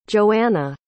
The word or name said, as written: joeanna